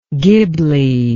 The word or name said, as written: ghibli